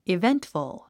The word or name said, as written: eventful